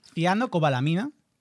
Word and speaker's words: cianocobalamina: Está, a ver si lo digo bien, sí. – La cianobalamina. – ¿Cianocobalamina? – Bien, ya ha empezado mal.